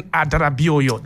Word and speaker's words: Adarabioyo: He alongside Tosin Adarabioyo.